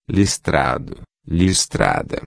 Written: listrado-a

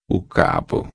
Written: cabo

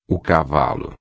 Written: cavalo

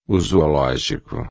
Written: zoologico

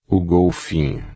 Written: golfinho

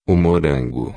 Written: morango

